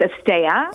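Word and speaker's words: asteya: What is asteia? Am I pronouncing that right? – So it's asteia.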